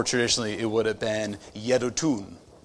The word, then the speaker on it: Jeduthun: And that according to, I was not completely sure how to pronounce that. And at first I looked up one thing, and it's just an obscure enough of a name that it's not that easy to find pronunciation guide. And there's this one YouTube video I ran across. It was 34 seconds of just Jeduthun, Jeduthun, Jeduthun, Jeduthun.